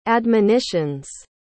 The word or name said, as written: admonitions